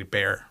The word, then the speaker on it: Bexar: It's spelled B-E-X-A-R. – It's spelled Bexar. – It's spelled Bexar, but it's actually Bear. – Bear. The X is silent.